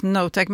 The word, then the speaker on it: Knotek: Ja, men jag tror det ska uttalas Notek, men Knotek känns ju roligare att säga.